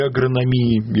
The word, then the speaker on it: агрономии: и Болотов известен как один из отцов отечественной агрономии или агрономии, я встречал такое ударение, раз про это заговорили, вот что Болтов пишет.